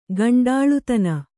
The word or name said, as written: ಕುಂಟಲತನ